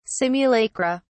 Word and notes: simulacra: British English

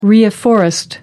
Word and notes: reafforest: American English

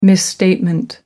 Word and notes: misstatement: American English

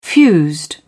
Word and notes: fused: British English

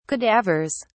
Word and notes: cadavers: British English

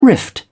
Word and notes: rift: British English

